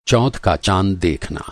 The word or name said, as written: चौथ